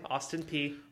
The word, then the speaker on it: Austin Peay: Because they started the season beating Austin Peay. Is that how you say it? – Austin Peay. – Austin Peay.